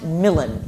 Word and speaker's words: Milan: In Milan, yes, which is pronounced Milan in this play, just because of the scansion in Milan.